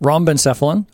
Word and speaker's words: rhombencephalon: And then there's the hindbrain or rhombencephalon.